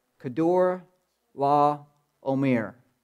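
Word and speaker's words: Chedorlaomer: Kedor, La, Omer. That's how you say it.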